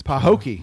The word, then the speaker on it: Pahokee: How do you pronounce it? Pahokee? It's Pahokee.